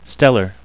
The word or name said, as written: stellar